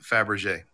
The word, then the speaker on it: Fabergé: If anybody is interested, Fabrige is spelled F-A-B-R-E-G-E. – And pronounced Fabergé.